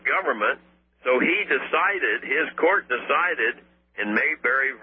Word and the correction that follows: Marbury: versus I'm sorry, Marbury, I'm saying it wrong, Marbury versus Madison, that the power to overthrow the acts of Congress, establishing the concept of judicial review, he called it, was constitutional.